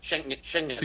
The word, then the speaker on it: Schengen: Schengen.